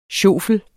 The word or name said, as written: sjofle